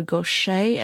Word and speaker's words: Gauthier: Next up, we've got Mary Gauthier, or Gauthier, as I've heard someone pronounce it, but it's spelled G-A-U-T-H-I-E-R.